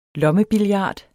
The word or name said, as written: lommebillard